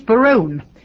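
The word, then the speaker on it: Berowne: Well, I think he's Barone, but I got this out of Shakespeare, and I've never met anybody in real life called Barone, but I think that's how he would pronounce it.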